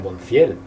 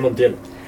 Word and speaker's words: Monthiel: De hecho, antes de empezar, estaba a punto de preguntarte si tú eras Montiel o Monciel, porque tiene una H ahí un poco sospechosa, digo, sí, tú eres Montiel.